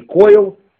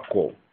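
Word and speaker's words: кол (קול): Например, слово кол, голос, куфвафламы, говорят не коил, как это должно быть по-ашкенаскому иврите, а кол.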